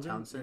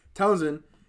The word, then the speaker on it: Townsend: Townsend. Yeah. – Townsend hit a buzzer beater.